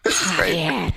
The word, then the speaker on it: Javier: New Yorker on YouTube, it says pronounce Javier like a more raspy H, like when you clear your throat. – Javier.